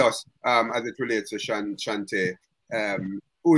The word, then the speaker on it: Chauntai: And her name is Chauntai Samuels? – Chauntai Samuels. – Chauntai? – Yeah, Chauntai, yeah. – How do I get... Is it spelled with T-A-L at the end? – No, it's actually spelled with T-A-I.